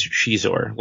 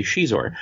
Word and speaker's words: Xizor: Everything that I've heard is Xizor. Like in the people say it, and in the audiobook, they say Xizor.